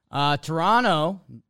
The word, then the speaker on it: Toronto: Toronto, don't pronounce that second T you've told me.